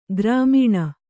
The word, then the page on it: ಗ್ರಾಮೀಣ: Kannada